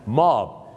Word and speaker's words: mob: ะก็คือลูกแกะหรือเนื้อลูกแกะนั่นเองครับที่จริงนะครับมันก็จะมีหลายคำนะฮะที่ลงท้ายตัว B นะฮะที่บ้านเราออกเสียงผิดอันนึงนะครับออกไปเลยครับพี่น้องครับออกไปเลยนะครับพี่น้องบ้านเราก็ไปออกเสียงว่าม็อบใช่ไหมฮะถ้าไปบอกฝรั่งครับ today we mop here ม็อบ here ก็จะเราจะมาถูพื้นที่นี่สิครับผมนะฮะม็อบเนี่ยมันไม่ได้นะครับฝรั่งจะฟังเป็น mop ม็อบที่จริงแล้วนะครับมันต้องออกเสียงว่ามอบนั่นเองครับอ่ะมอบ M O B มอบเหมือนกับขอมอบดอกไม้ในสวนเนี่ยนะฮะมอบแต่ถ้า